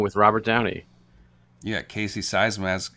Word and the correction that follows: Siemaszko: How do you say his name? Seismas? Siemaszko. Siemaszko, that's right.